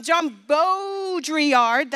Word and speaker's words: Baudrillard: Jean Baudrillard, that's how you say his name.